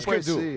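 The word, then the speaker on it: Hüsker Dü: Então, se eu errar a pronúncia, vocês me corrijam. Husker Du. – É, tipo Husker Du. É isso aí.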